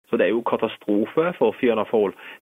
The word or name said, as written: fianna fail